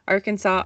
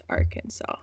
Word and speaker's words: Arkansas: Well, it's not technically illegal or punishable to say it wrong with a hard S at the end of the name. Arkansas, obviously, is legally supposed to be pronounced Arkansas. – Oh, yeah. Not Arkansas.